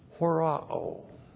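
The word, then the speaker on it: Horao (ὁράω): And then we have the verb horao. Horao. H-O-R-A, long O.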